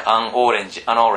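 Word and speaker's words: an orange: これとこれつなげてさね、そう教えればいいじゃんね、アンオレンジ、アンオレンジとかさ言えばいいじゃんね、ゴー女ピクニック、ゴー女ピクニック、女ピクニック、ん女とピクニック行くみたいなさ、これ俺昔あの予備校で習ってさ、予備校じゃねえや、夏期講習かなで習って二度と忘れないもんね。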